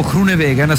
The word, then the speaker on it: Groenewegen: Nel frattempo il gruppo viene sempre condotto dagli uomini della Unibet Ross Rockets, che è la squadra di Dylan Groenewegen, o Groenewegen se preferite la pronuncia olandese,